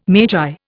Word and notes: magi: Indian

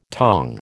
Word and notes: tong: American English

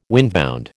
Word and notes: windbound: American English

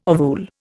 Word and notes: ovule: Indian

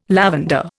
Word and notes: lavender: Indian

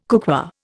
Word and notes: cookware: Indian

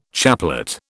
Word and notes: chaplet: British English

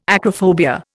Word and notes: acrophobia: Indian

